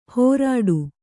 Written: ಹೋರಾಡು

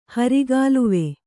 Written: ಹರಿಗಾಲುವೆ

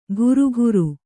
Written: ಘುರುಘುರು

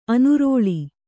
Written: ಅನುರೋಳಿ

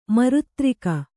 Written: ಮಿರುತಾರಗೆ

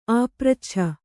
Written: ಆಪೃಚ್ಛನ

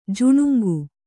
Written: ಜಾಣಾಂಕಿ

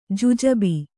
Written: ಜುಜುಬಿ

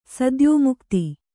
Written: ಸದ್ಯೋಮುಕ್ತಿ

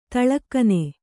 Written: ತೇಲುಗಣ್ಣು

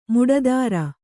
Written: ಮಟ್ಟತರ

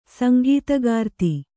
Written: ಸಂಗೀತಗಾರ್ತಿ

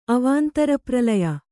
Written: ಅವಾಂತರಪ್ರಲಯ

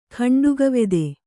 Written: ಖಂಡುಗವೆದೆ

